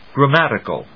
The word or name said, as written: grammatical